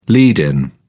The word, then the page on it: lead-in: British English